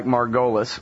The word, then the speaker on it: Margolis: Just spell it like Margolis, like I used to say it before I finally learned how to say it right.